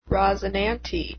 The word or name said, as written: rosinante